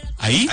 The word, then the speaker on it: Ahí: ¿Ahí? – Ahí. – O sea, Ahí. – A-H-I. – Ahí. Ahí. Con acento en la I.